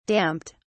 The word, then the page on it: damped: British English